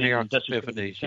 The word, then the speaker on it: Antiochus Epiphanes: I think you pronounce the guy's name Antiochus Epiphanes or something like that. – Right, he went Antiochus Epiphanes, yes.